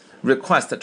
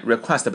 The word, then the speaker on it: request: Request, 中音在后面 request, 不要念成 request.